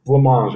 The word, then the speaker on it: blancmange: I think it's blancmange. I've heard it pronounced blancmange. – All right. All right. If you're French, it's blancmange, which is like white eat.